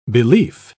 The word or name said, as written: belief